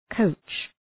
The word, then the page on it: coach: kəʋtʃ